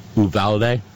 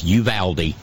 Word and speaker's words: Uvalde: I was saying Uvalde. Nope. It's a Texas word, and it's Uvalde.